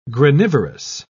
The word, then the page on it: granivorous: British English